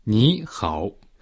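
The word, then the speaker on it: Ni hao (你好): A pronúncia de ni é parecida com a palavra em inglês ni e hao similar ao hao.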